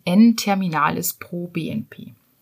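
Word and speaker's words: N-terminal pro-BNP: Genau, das ähm, heißt ausgesprochen N-terminalis pro BNP.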